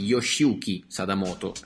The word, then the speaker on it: Yoshiyuki Sadamoto: Piccola curiosità per confermare la stravaganza di questo album: la copertina di Pilgrim è stata disegnata dal creatore dei personaggi di Evangelion, e cioè Yoshiyuki Sadamoto, anzi Yoshiyuki Sadamoto, ecco, la pronuncia giapponese non mi fa difetto a differenza di quella inglese.